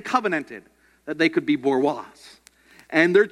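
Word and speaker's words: bourgeois: There was the Borois, spelled really weird, children. It looks like Borgiosis or something, you know, but it's French. The French don't pronounce half their things, and so it's Borois, right?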